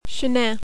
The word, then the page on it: Chenin: French